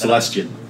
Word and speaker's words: Celestian: It's very clear that it was designed to match this Greyhawk god Celestan, right? – Mm-hmm. – Or however it's pronounced. – Celestian.